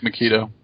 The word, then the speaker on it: Megiddo: I believe it's Magetto. Is the name of the valley. But that it just caught my eye, and I was like, well, yeah, you could pronounce that Magetto, although they probably don't. It probably looks like Megiddo. – It is Megiddo, because it's in 2 or 3, they actually say the name.